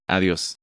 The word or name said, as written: ¡Adios!